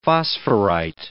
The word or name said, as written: phosphorite